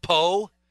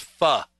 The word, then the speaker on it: pho: Sent people to a restaurant when I've come home, and they said, oh, did you try any of that po? Did you have any po? No, I learned a long time ago. It's pho. That's it's spelled po, but it's pronounced pho.